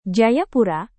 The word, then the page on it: jayapura: Indonesian